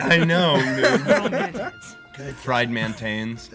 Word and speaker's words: MTNS: Is that how you pronounce it? – God bless you. – Well, it's mantans.